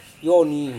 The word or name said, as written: yoni